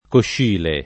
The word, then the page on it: Coscile: lingua italiana